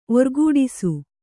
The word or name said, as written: ಓರ್ಗುಡಿಸು